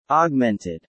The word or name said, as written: augmented